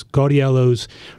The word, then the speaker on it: Gaudiello's: To round out the tri-state area, I'm going to go down to Delaware, Wilmington, Delaware, Gaudillo's, Gaudiello's. I probably pronounce it wrong every other time, but G-A-U-D-I-E-L-L-O, apostrophe S, Gaudiello's, Trolley Square,